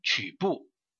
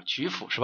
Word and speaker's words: 曲阜: 第二个就是鲁国国都曲部啊，应该说叫曲阜是吧？叫曲什么？呃，同学呃打个发音过来啊。叫曲什么？曲阜还是曲阜啊？哎，啊，曲阜是吧？好，呃，鲁国的国都曲阜啊，它是按周制建制的啊。